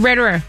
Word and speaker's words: Roederer: R-O-E-D-E-R. How do you say it? – Roederer.